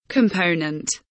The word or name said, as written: component